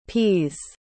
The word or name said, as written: peas